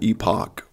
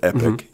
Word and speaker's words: epoch: Well, in the shifts that we see take place there are, it's according to how you pronounce it, but epoch or epic, E-P-O-C-H, is the transition,